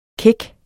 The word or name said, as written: kæk